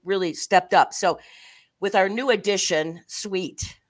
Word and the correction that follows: Sweat: So how do you spell Sweet? – Well, I know it, but it can be pronounced differently. – It's W-E-E-T. – I know it, but this is W-E-A-T. I get that. – Oh, I love you, Lynn. It's Sweat. – It's Sweat. Okay, so Sweat.